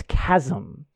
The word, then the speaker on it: chasm: I heard it in a video, and I'm not gonna like throw the person under the bus in the video, but they said chasm, and I'm like, it's chasm. That's it's a cat. Like, it's objectively pronounced chasm. Like that you don't. And I get it. It's spelt chasm, but I was like, that's not the word.